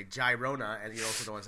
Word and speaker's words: Girona: This will be against Girona. I always say it wrong. – Girona! – Girona. All right.